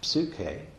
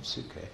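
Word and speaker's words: psuche (ψυχή): It was then pronounced psuche, but spelled basically the same, psuche.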